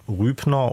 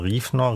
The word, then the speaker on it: Rübner: Tuvia Rübner oder Riefner, Riefner ist die hebräische Aussprache, hat darauf gerne mit Humor reagiert und sich als hebräisch-deutschen Schriftsteller bezeichnet.